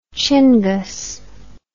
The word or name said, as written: chingus